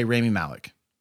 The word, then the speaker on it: Rami Malek: I'm going to say Rami Malek.